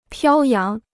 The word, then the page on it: 飘扬: piāo yáng